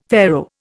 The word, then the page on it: Pharaoh: Indian